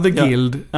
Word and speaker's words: The Guild: Eller hur uttalas detta? – Ja, The Guild. – Ja, jag har hört 4 olika uttal senaste dagarna.